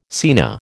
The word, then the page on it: scena: American English